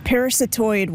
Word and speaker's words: parasitoid: Am I pronouncing this right? Parasitoid? – Yes, parasitoid wasps, or you could also say parasitic wasps.